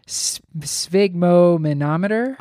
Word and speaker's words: sphygmomanometer: it has sphygmomanometer.